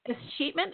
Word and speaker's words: escheatment: but it's escheatment.